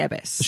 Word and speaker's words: abbess: She's going to run an abbess. Is that how you pronounce it? – Well, she is the abbess.